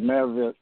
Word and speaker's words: Merrillville: He is out of Merrillville, Indiana. I'm saying that right, correct? It's Merrillville, Indiana? – Yes, sir. Merrillville. Yep, Merrillville, Indiana.